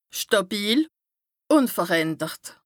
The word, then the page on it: stàbil, ùnverändert: Bas Rhin; Strasbourg